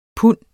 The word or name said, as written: pund